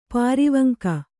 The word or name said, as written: ಪಾರಿವಂಕ